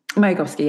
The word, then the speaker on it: Mayakovsky: And this was inspired by a poem by Vladimir Mayakovsky. Do I pronounce that well? – Mayakovsky, yeah.